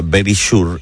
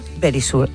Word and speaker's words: Verisure: Ahora hablo con Manuela, lo que ocurre es que Securitas Direct ahora se llama Verisure y vosotros no podéis estar así tan tranquilos y deberíais recordarlo.